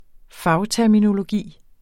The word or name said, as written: fagterminologi